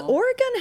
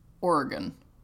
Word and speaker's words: Oregon: So here it's Oregon. Yeah. Like the thing in your body.